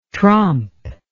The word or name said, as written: trompe